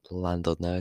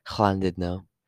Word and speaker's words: Llandudno: If you've ever been to Landudno, it's not actually pronounced Landudno, it's pronounced Llandudno.